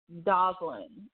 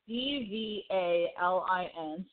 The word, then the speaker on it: Dvalin: Dovlin. It's D V A L I N. So I am going to pronounce it Dvalin.